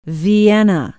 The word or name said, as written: vienna